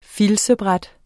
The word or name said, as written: filtsbræt